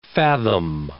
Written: fathom

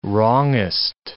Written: wrongest

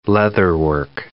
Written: leatherwork

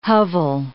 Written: hovel

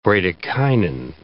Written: bradykinin

